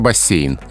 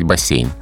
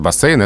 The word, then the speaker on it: бассейн: А, но словари, адресованные работникам эфира, скорее за бассейн. Угу. Скорее за мягкое произношение. То есть здесь мы с тобой будем говорить бассейн. Так. Но из этого не следует, что вариант бассейн — это грубая ошибка. Нет, так тоже можно.